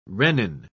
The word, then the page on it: rennin: British English